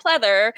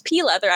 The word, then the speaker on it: pleather: pleather. I didn't even know how to pronounce the name at the time. I'm pretty sure I pronounced it as pea leather.